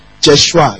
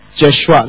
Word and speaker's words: Jeshua: So what's the pronunciation here? Jeshua. You see? Jeshua. Not Je.